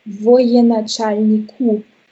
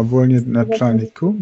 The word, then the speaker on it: Военачальнику: Aber das spricht man Военачальнику aus. – Ah, Военачальнику.